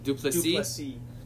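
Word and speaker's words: Du Plessis: Drikus Duplessis? – Duplessis.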